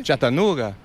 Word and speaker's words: Chattanooga: Chattanooga.